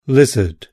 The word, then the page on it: lizard: British English